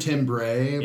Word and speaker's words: Timber Timbre: Timber Timbre.